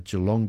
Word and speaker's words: Geelong: Discovering the Impressionists, Paul Durand-Ruel, art dealer, among the artists at Geelong Gallery, and runs till October 11. We have very little time, but I have to say I was very impressed, Marianne, with your pronunciation of Geelong.